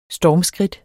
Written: stormskridt

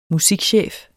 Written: musikchef

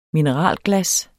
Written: mineralglas

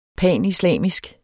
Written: panislamisk

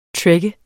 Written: trek

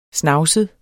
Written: snavset

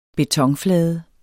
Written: betonflade

